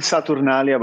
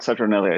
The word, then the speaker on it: Saturnalia: we pronounce it Saturnalia, but Saturnalia is just as correct.